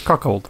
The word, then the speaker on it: cuckold: Kackold. Kackold. Hur uttalas det? Cuckold.